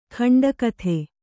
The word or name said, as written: ಕಂಠೋಕ್ತ